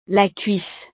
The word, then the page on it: la cuisse: frances